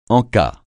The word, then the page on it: en-cas: francais